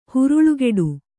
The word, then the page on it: ಹರಳುಗಟ್ಟು: Kannada